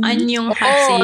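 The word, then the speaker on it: Annyeonghaseyo (안녕하세요): My God, I butchered it. Annyeonghaseyo. – Annyeonghaseyo. – Annyeonghaseyo. Oh, that's a good pronunciation.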